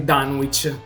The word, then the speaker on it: Dunwich: Allora, tecnicamente eh, la pronuncia corretta sarebbe Dunwich ma onestamente noi sin dagli albori dal 2017 lo pronunciamo in modo improprio e quindi Dunwich Buyers Club, cosa per cui ci prendono in giro ancora oggi.